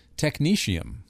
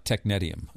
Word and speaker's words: technetium: And thank you for correcting me on how to pronounce it, because I've been pronouncing it wrong this whole time. Technetium. Not technetium. I used to call it technetium. That's right.